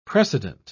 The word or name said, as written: precedent